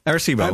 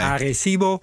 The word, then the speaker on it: Arecibo: Is het nou Arecibo of Arecibo? Of Arecibo in Arecibo. Puerto Rico. Arecibo, volgens mij. – Arecibo.